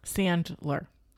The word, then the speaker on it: Sandler: You know, Adam Sandler, is it Sandler? – Sandler. S-A-N-D-L-E-R. – Sandler. So I was saying it right.